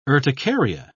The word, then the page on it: urticaria: British English